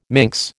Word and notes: minx: American English

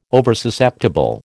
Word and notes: Oversusceptible: American English

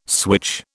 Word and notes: switch: British English

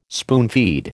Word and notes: spoonfeed: American English

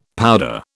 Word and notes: powder: British English

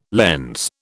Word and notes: lens: British English

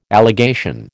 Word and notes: allegation: American English

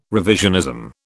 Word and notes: revisionism: British English

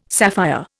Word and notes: sapphire: Indian

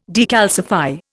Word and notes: decalcify: Indian

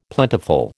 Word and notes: plentiful: American English

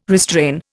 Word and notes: restrain: Indian

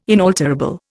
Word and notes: inalterable: Indian

